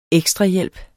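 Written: EKSTRAHJÆLP

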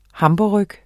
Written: hamburgerryg

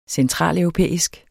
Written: centraleuropæisk

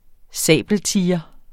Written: sabeltiger

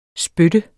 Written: SPYT

